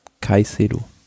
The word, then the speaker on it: Caicedo: Caicedo.